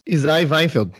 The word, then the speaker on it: Isai Weinfeld: Trabalhou com Isai Weinfeld. Falei certo? – Isai Weinfeld, mas pode ser também.